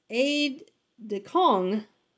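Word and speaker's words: aide-de-camp: So aide de camp, and this is actually has me pronouncing it as aide de cong. So aide de cong, I'm not sure why, but it, it, it looks like aide decamp or decamp, like the plane.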